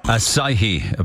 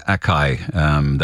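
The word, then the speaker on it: açaí: Asahi, apparently the correct way to pronounce what I was always calling acai.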